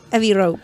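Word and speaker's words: Abbey Road: Abbey Row.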